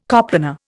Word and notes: coplanar: Indian

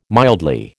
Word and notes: mildly: American English

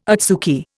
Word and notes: adsuki: Indian

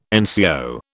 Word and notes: NCO: British English

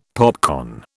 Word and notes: popcorn: British English

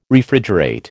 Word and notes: Refrigerate: American English

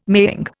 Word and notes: maying: Indian

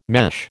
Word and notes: mesh: American English

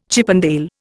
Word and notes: Chippendale: Indian

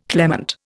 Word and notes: clement: Indian

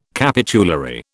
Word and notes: capitulary: British English

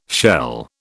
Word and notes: shell: British English

